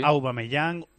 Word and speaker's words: Aubameyang: Aubameyang, a mí me sale Aubameyang, sí. – Aubameyang, y a ti, Evangelio. – Yo le he llamado de todas las formas posibles. – Sí. – Aubameyang, Aubameyang, Aubameyang, sí.